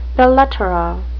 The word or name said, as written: belles-lettres